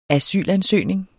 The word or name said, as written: asylansøgning